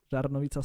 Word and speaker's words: Žarnovica: At the point I was contacting you, I suspected he came from a village or a town called Žarnovica. Is that the correct pronunciation? – Yes. Žarnovica, Slovakia.